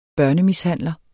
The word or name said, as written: børnemishandler